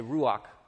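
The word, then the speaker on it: ruach (רוח): Now, that, I'm not clearing my throat at the end. That's like the proper Hebrew pronunciation, but I'm just going to say ruach.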